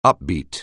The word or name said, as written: upbeat